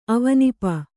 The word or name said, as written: ಅವನಿಪ